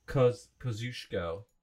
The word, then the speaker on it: Kosciuszko: Cusco. No, how do you say it? – You say it. – It's spelled Kosciuszko. – Kosciuszko.